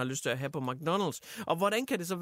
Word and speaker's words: McDonald's: McDonald's. McDonald's er faktisk den rigtige udtale, jeg er ret sikker på mig i.